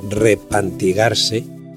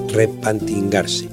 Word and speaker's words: repantigarse: Pues bien, por las razones que se acaban de exponer, el verbo repantigarse, arrodillarse en el asiento y extenderse para mayor comodidad, lo define el diccionario de la Real Academia Española, se pronuncia mayoritariamente repantingarse y esporádicamente hasta rempantingarse.